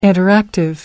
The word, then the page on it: interactive: American English